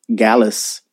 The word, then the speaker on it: Gyalis: So the next song is called Gallis by Capella Gray, and it's spelled G-Y-A-L-I-S.